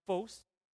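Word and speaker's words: phos (φῶς): In the Greek, the word actually means is phos, if you were to pronounce it, phos.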